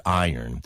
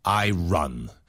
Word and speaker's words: iron: And I should apologize here for the difference between spelling and pronunciation of this word iron. – Mm-hmm. – I know the spelling is I. R-O-N, it looks like it should say I run. But the pronunciation is like this, I-O-R-N, iron.